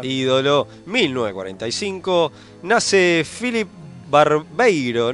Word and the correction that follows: Barberio: ¿Lo pronuncié bien? Eh, corríjame. – Barberio.